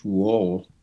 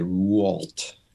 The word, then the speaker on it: Rouault: Well, of course, my most favorite painter is George Rouault. What Americans say, Rouault, which is really one of the rare, well-known Christian or religious subject painter in the modern world.